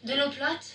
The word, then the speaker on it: eau plate: De l'eau plate.